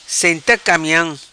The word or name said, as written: Senta Camiam